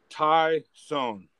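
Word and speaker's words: Tyus: And you got to pronounce it this way. Tyson. It's not Tyson. It's Tyson.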